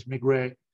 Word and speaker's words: Maigret: You know, in the UK they say Maigret, but it's Maigret. – Yeah. – Yeah, Maigret. We did the first two Maigret films that we did was through our TF1 deal like 5 years ago, which were the two Jean Gabin ones.